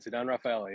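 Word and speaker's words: Ceddanne Rafaela: Sedan Rafaela. Tell me how I mispronounced his name. – No, that's good. That's good. Sedan Rafaela. Yep.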